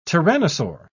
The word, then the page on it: tyrannosaur: British English